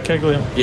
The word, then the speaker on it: Caglianone: So we had Caglianone, is that how you pronounce his name? – Yeah, Jack Caglianone.